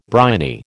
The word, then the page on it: briny: American English